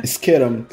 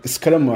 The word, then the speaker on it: Skeram: Skeram. skram acho que se pronuncia é S-K-E-R-A-M.